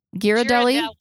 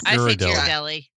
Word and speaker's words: Ghirardelli: Do you guys use a hard G or a soft G on that? Ghirardelli. – Oh, it's Ghirardelli. – Yeah, I say Ghirardelli. – Huh. I'm going with the GH as a hard because it's hard and ghost.